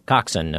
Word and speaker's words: coxswain: The thing that's always fascinated me is that there's this other person on the boat, the coxswain, if I'm saying that right. – You are.